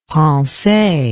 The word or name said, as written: pensee